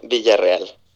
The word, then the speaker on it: Villarreal: Yeah, so it's Hiram Villarreal.